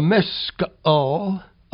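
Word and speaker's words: Maschil: This psalm has an inscription, a Miscol of Asaph. That's kind of a difficult word, and I think it is correctly pronounced with three syllables.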